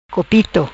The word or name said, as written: Copito